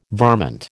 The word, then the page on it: varmint: American English